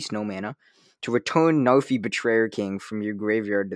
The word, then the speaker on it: Narfi: And then we have Narfi. Am I pronouncing that wrong? – Narfi. I don't know. Narfi? No, it can't be. It's N-A-R-F-I. – Narfi. – Narfi.